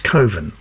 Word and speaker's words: Cwfen: So there we are, Coven, which isn't spelt anything like it sounds. C-W-F-E-N is how you spell it, but it's pronounced Coven, apparently.